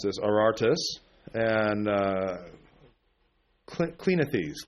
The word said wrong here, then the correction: Cleanthes: Clinith, I can never pronounce Greek names. Cleanthes. There we go.